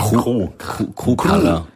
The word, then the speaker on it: Chrupalla: Wie spricht man das aus? Chrupalla? – Nee, Chrupalla. Chrupalla. – Mit K. – Das Chrupalla. K vorne, ja.